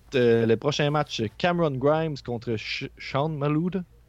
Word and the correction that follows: Maluta: Comment on dit ça, son nom C'est Sean Maluta.